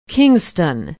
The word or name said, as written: kingston